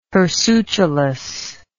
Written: hirsutulous

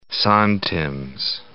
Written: santims

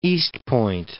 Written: East Point

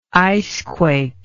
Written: icequake

